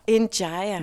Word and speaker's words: Ndiaye: Jeg kan se, at jeg ikke udtalte dit efternavn helt rigtigt. Vil du sige, hvordan man siger det? – Ndiaye. – Ndiaye.